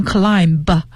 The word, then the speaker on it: climb: 好，再往下一个我觉得也是值得注意的，就是 climb 这个单词呢 spelled as C-L-I-M-B 而这个 B 它是不发音的，所以你不能读成 climb，而是读成 climb 就结束了。